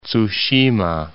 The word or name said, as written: Tsushima